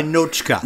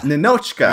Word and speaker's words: Ninotchka: Let's start with Ninotchka. – So close. You're getting closer. You're getting closer to the right pronunciation. It's Russian, so yeah. Ninotchka. – Ninotchka.